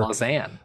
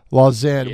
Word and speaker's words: Lausanne: CPA right now leads Lausanne. I'm sure I'm saying that wrong, but that school down in Memphis where – Lausanne. – Lausanne. Yes.